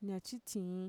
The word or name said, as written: ñatxitxiin